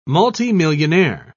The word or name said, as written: multimillionaire